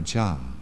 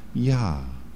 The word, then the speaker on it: Jah: or Jah. I'm not good at it. I pronounce the J too strong. It's more like Yah.